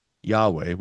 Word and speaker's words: Yahweh: Yahweh, we pronounce it sometimes.